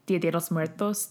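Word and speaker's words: Día de los Muertos: I'm also hearing similarities to kind of Mexican Dia de los Muertos, Day of the Dead. – That was a really good pronunciation. That was probably the best pronunciation we've had on spirits at any point.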